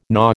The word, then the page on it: gnaw: American English